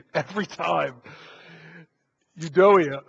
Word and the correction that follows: Euodia: someone help me out here. You know, I even read this out. I had the computer read it out loud to me so I wouldn't butcher it. And I was sitting at home, practice saying, Euodia. There it is. I got it.